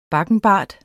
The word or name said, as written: bakkenbart